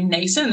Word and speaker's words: nascent: So look, I think the first thing to note is that the femtech industry is very, very nascent. There's not a lot of maturation. Is that how you pronounce it? Yeah.